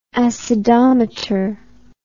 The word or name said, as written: acidometer